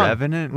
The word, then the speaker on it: Revenant: The Revenant with the Revenant. – Bear? – Revenant. – Revenant with the bear. The horse with the Godfather. – You never know how to King say Kong. Revenant? – No.